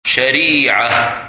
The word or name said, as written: Shariah